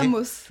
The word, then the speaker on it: vamos: Bamos. Eh Bamos. Le V se prononce B.